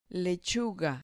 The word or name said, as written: lechuga